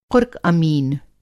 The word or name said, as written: क़ुर्क़-अमीन